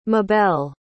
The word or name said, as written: mabelle